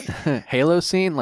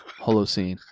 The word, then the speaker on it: Holocene: Halocene? Like, no, it's an O. Holo. That's the most common one. People call it Halocene. I'm like, no, it's an O. Holocene.